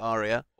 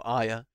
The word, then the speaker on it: Arya: Speaking of Arya, or Aya, as she called herself, but it's spelt with an R.